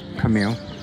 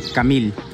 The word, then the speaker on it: Camille: Camille. – How do you say it, like, with the Spanish, like? – Camille.